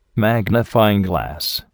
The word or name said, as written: magnifying glass